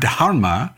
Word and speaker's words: dharma: So I spent a week with a Sanskrit scholar who did her best to get me to pronounce the H in dharma.